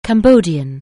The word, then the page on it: Cambodian: anglais